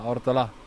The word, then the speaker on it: Ortolà: Ortolà, Ortolà, com es pronuncia bé el cognom? – Ortolà. – Ortolà, perquè venint de Terres Valencianes, Ortolà, Sí.